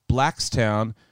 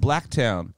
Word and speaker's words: Blacktown: My friend over there told me that town is pronounced Blackstown, but I thought it was pronounced Blacktown.